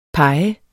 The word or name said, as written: pege